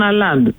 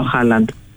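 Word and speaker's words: Haaland: τον Εμπαπέ και τον Αλάντ. Λέγανε Αλάντ, ποιος είναι ο Αλάντ, και μετά καλωδίωσα μετά από την επόμενη μέρα ότι ήταν ο Χάλαντ. Αλλά έτσι τον προφέρουν οι Γάλλοι.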